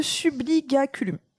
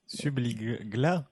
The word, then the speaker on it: subligaculum: Le subligaculum, voilà. – Subligla sous leur toge.